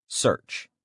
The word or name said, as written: search